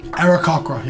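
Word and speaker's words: Aarakocra: I'm a race called Arakokra. Is that how you say it? – Okay. Arakokra, yeah.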